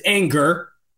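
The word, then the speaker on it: Ehinger: We'll trade Parker Anger, who, that's not how his name is spelled, but it's Anger, for Chavarius Ward, who was undrafted.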